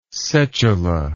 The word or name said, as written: setula